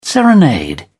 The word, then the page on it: serenade: British English